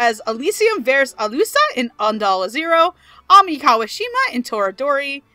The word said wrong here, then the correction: Toradora: I'm sorry, you just pronounced both of those wrong. – Ami. – Ami. It's Ami. – Kawashima. Yep. Kawashima in Toradora.